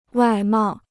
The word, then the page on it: 外贸: wài mào